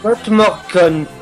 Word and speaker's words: God morgen: Ça se dit pas comme ça, ça se dit pas comme ça. God morgen. – God morgen. God morgen. Voilà. – Vraiment, je pense que je vais me rabattre sur l'anglais. Hein, en fait, tu l'as prononcé comme un allemand plutôt.